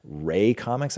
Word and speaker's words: Rae Comics: Rae Comics, I believe is how you say it. R A E Comics.